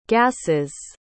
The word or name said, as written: gasses